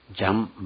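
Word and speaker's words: Jean: 主人公のジャン・バルジャン、ジャンというのはヨハネのことなんですね。フランス語ではジャンという発音であるんです。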